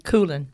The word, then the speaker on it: Coolen: Is it pronounced Coolen? – Coolen. – Coolen. – Yep. Coolen. It's a Dutch name.